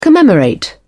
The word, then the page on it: commemorate: British English